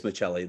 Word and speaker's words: Maccelli: And it's Matthias Michelli. That's the pronunciation there.